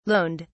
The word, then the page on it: loaned: British English